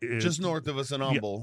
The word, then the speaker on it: Humble: But when you're around here, you don't pronounce the H for whatever reason. Even though if you were saying the word humble, you would say humble.